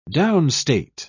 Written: downstate

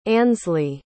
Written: ansley

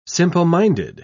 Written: simpleminded

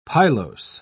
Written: pilose

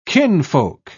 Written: kinfolk

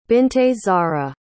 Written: binte zahra

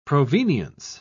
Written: provenience

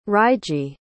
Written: reiji

